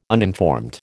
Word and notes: uninformed: American English

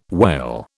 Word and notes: wail: British English

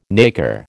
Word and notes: nacre: American English

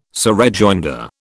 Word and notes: surrejoinder: British English

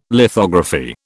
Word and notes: lithography: British English

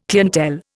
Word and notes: clientele: Indian

